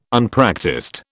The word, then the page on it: Unpractised: British English